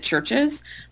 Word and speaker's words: CHVRCHES: And the other thing that I have been listening to is a band called, I presume you pronounce it Churches, but it's it's all capital letters, and the U they spell with a V, right? Okay. So it's CHVRCHES.